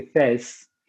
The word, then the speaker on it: Fès: En, français, on dit Fès, mais en mm. fait, c'est Fès. – Mm. – Voilà, on le prononce Fès.